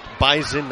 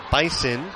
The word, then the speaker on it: Bison: It is pronounced Bison, not Bison. They're very particular about it.